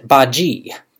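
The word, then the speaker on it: Badguy: Dominic Bad Guy, pronounced Baji, played by Gervais.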